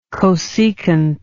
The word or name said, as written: cosecant